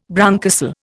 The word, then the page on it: bronchocele: Indian